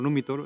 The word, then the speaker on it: Numitor: el hermano malo, y el otro es Numitor. O Numitor, exactamente eso es como se pronuncia,